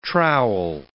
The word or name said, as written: trowel